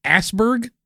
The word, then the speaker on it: Asberg: According to their team, Peter Lindbergh and Dennis Asberg. – I think that's pronounced Asberg. – Oh, Asberg? Okay. I don't know. I don't speak Swedish.